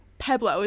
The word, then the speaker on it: Pueblo: They pronounced it wrong. Pueblo. It's totally Pueblo. It's like Pebble.